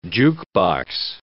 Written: jukebox

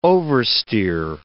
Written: oversteer

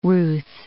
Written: Ruth

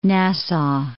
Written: Nassau

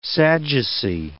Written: Sadducee

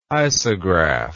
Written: isograph

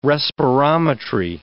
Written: respirometry

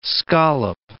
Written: scallop

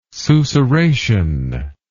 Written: susurration

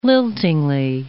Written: liltingly

